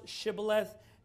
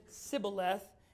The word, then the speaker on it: Shibboleth: So what we see here is that they could not pronounce Shibboleth. They said Sibboleth.